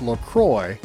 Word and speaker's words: LaCroix: Now, officially, it's pronounced LaCroix, so it rhymes with enjoy, not La Croix or La Croix, like the French might say it.